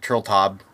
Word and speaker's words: Turteltaub: It was directed by this guy, John Turteltaub. I don't know if I'm saying his name right, but that's what it looks like, Turteltaub.